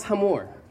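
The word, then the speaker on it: hamor (חמור): This is your Hebrew word for donkey. It's pronounced hamor. And if you switch the O and the A, you get the Arabic version.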